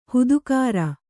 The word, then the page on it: ಹದಗಾಱ: hadagāṛa